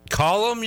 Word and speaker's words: Callum: C-A-L-L-U-M, Callum. Callum is what you're going with? – Callum.